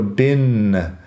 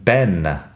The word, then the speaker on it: been: Bin, yo lo pronuncio bin como si fuera una i corta. Hay otros americanos que lo dicen ben, como la forma corta de Benjamin, ben. Y los británicos que dicen bean con la i larga. O sea que la pronunciación varía un poco, es regional. Para mí, bin de toda la vida, pero no todo el mundo va a pronunciarlo exactamente así.